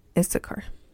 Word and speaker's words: Issachar: I don't even know if I'm saying it right. It's like Isaac. Issachar.